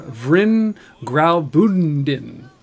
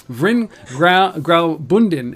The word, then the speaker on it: Vrin Graubünden: It's basically an advertising campaign for Vrin Graubünden. Oh, totally butchered that in. Vrin Graubünden,